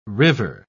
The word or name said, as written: river